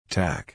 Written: tack

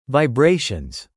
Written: vibrations

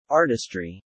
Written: artistry

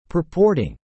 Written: purporting

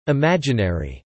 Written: imaginary

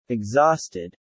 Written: exhausted

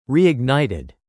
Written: reignited

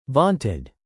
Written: vaunted

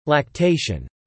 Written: lactation

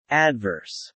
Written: adverse

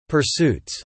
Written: pursuits

